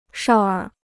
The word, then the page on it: 少儿: shào ér